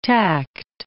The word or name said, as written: tact